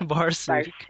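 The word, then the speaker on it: Barsuk: What? How did you say it? – Barsook. – Let's go with that. That sounds better.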